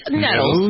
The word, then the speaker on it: Nettles: Do I pronounce Nettles? Is it Nettles? – Pretty English sounding of you. It's Nettles.